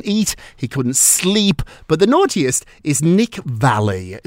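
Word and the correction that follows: Viall: Is that how I pronounce his last name? – Yeah, Nick Vile.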